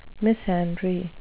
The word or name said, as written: misandry